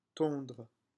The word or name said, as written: tondre